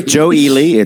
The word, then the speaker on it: Joe Ely: Joe Ely. That's how you say his name.